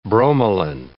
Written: bromelain

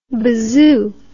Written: bazoo

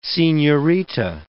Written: senhorita

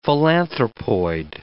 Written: philanthropoid

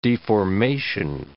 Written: deformation

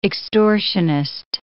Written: extortionist

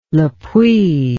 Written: le puy

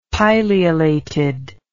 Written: pileolated

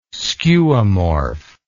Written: skeuomorph

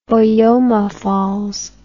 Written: boyomafalls

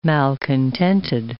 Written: malcontented

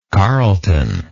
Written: carlton

